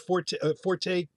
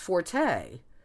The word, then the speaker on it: forte: Oh, no, I'm going to interject, Ross, because I understood that the forte pronunciation came from the Italian, which is why it's pronounced forte, as opposed to the French fort or four, or however you say it, with that R, that N, T that I can't pronounce.